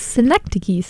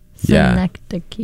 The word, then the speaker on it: Synecdoche: And he informed us that it is Synecdoche, New York, not Synecdoche. – Yes, it's Synecdoche. So you pronounce every syllable. Synecdoche. Yeah. – And the CH is a K. – K. Key. It's a key.